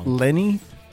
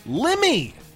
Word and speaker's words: Lemmy: Lenny? – Lemmy! – No, but that's what they said. – They called him Lenny. Like, hey, it's Lenny. Hey, it's Lenny over there from Motorhead.